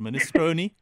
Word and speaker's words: minestrone: It's minestro. Isn't it minestrone? – Wait a second. Are you saying there's another way to pronounce it? – That is actually how you pronounce it. Minestrone.